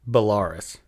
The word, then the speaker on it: Belarus: It is Belarus. – Belarus. – Belarus. It's Belarus, clearly. And so... Is that seriously pronounced Belarus? Yeah, Belarus.